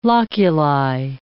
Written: loculi

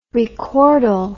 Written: recordal

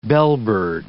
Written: bellbird

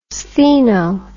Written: Stheno

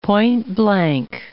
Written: point-blank

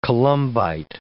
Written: columbite

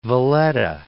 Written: Valletta

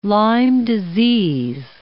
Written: Lyme disease

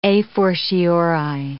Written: a fortiori

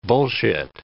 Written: bullshit

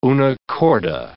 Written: una corda

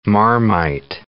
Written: Marmite

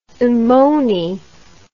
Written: ammoni

